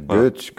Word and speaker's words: Goetz: Derrière vous figure donc l'original du dessin en couleur, qui figure bien sûr à la couverture de votre livre, et signé Walter Goetz. Je le prononce peut-être mal. – G-O-E-T-Z. Monsieur Goetz, comme un Goetz.